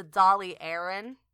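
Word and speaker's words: Dáil Éireann: Yes, I believe it's the Dolly Aaron. I believe is how you pronounce the Irish Republic's parliament.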